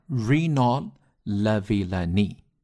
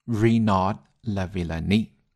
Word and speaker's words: Renaud Lavillenie: 他呢保持了 21 年的 6 米 15 的世界纪录，然后这个 Renaud Lavillenie 直接给他打破了，直接往上提了一厘米， 6 米 16。那么这个人名呢不是特别好念啊，我们可以一起来读一下 Renaud Lavillenie， Renaud Lavillenie， OK，就后面的那个 D 呢就不用发音了，这是一个法语过来的一个人名。